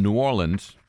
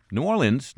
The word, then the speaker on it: New Orleans: And for all those people who say, well, how do you people down there really pronounce the name of the city? There were two examples right there from New Orleans. New Orleans, New Orleans, New Orleans, Louisiana.